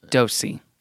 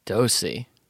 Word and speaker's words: Doose: Okay, the guy who names the market is named Taylor Dosey, but the last name is spelled D-O-O-S-E. Is that weird to you, or is that like whatever? – D-O-O-S-E? – Yeah. – And it's pronounced Dosey. – Dosey.